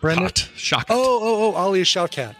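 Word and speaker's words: Alia Shawkat: Alea Shawkat.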